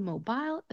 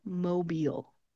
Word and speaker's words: Mobile: This city in Alabama is not called Mobile, it's called Mobile. Mobile. If you listen to my pronunciation, you can hear that soft eel sound that I'm making at the end of the word. It is not aisle, instead it's eel.